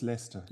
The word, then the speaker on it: Leicester: Ich habe es immer Leicester genannt, aber Sie sagten gerade Leicester, ne? So spricht man es aus. – Das machen alle gerne, aber es ist Leicester.